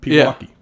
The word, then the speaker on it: Pewaukee: But anyways, before we get to basketball, you are a Pewaukee, Wisconsin native. Am I saying that right? – Pewaukee. Yeah. Yep. Pewaukee.